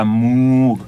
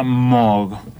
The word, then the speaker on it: Moog: En un momento le dicen, ¿cómo se pronuncia tu apellido? ¿Se pronuncia como una vaca, Moog? Y él dice que no, que en realidad no, que se pronuncia Moog.